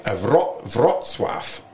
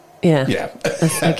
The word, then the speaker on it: Wrocław: Breslau is actually now in Poland, and it's pronounced, I think, Wrocław, which...